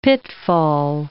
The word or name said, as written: pitfall